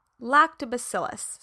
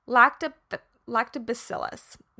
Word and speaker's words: lactobacillus: Lactobacillus. I always add on an extra syllable, a syllable. lactobacillus.